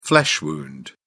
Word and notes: flesh wound: British English

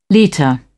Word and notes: litre: British English

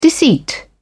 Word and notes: deceit: British English

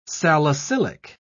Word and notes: salicylic: British English

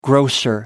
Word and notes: grocer: American English

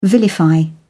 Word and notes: vilify: British English

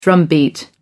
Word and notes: drumbeat: American English

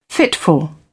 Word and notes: fitful: British English

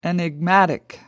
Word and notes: enigmatic: American English

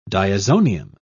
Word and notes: diazonium: British English

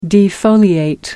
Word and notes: defoliate: American English

